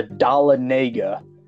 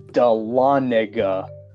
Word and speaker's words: Dahlonega: and he pronounced it the Dahlonega. That is not how it's pronounced. It is pronounced Dahlonega.